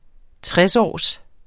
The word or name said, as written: tresårs